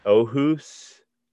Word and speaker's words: Aarhus: He is a professor of philosophy at Aarhus University.